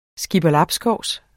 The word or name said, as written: skipperlabskovs